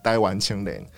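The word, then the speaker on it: 台灣青年: 对，我们今天谈论台湾民报，一开始其实是一群留学生到东京成立的这个台湾青年哦，要用台语发音哦，因为它的封面上面其实是有台语罗马字哦，台湾青年。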